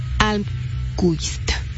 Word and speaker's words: Almqvist: Almqvist.